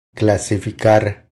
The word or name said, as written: Clasificar